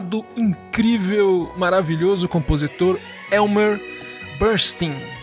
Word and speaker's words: Elmer Bernstein: E tanto que, para diferenciar no mundo musical, o Elmer que, o, cujo nome a gente pronuncia Bernstein, ele era conhecido como Bernstein do Oeste, por causa de Hollywood.